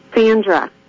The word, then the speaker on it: Sandra: This is Sandra. – Did you say your name again, please? – Sandra? – Sandra? Did you say Sandra or Andra? – Sandra. Like Sandy.